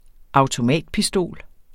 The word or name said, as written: AUTOMATPISTOL